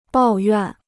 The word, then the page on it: 抱怨: bào yuàn